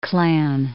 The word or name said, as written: Klan